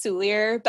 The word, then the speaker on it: Soulliere: I say it the English way, which is Soulier, but I'm sure I'm butchering it because it's French.